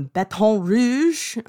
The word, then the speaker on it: Baton Rouge: He had been living in um, Baton Rouge um, for the past 10 years. Is that how you say it? – you nailed it.